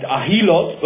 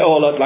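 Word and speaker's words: Oholot: This is a Mishnah in Ohelot, which we said last time may be pronounced Ahilot, but let's not get into that now. Let's call it Ohelot like people normally do.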